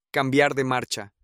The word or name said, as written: cambiar de marcha